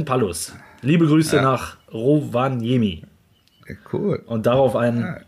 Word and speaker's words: Rovaniemi: Rovaniemi, ja.